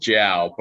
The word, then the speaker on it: Jaue: It's pronounced Jow, but I'm actually going by Jack these days.